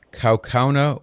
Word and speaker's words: Kaukauna: Is it Kaukauna, Wisconsin? Am I saying the name right? – Yes.